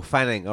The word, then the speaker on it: Fanning: Did you say his name is Mike Fanny? – Mike Fanning. – Oh, Fanning. All right, okay.